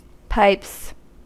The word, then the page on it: pipes: American English